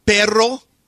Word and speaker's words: perro: What word do you roll your R on? – If it's double R. – So carro. Churro. – Churro. Sí. – Perro. Or perro.